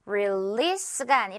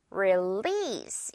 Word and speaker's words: release: release가 아니라 release. 이렇게 길게 늘어뜨리면서 one more time.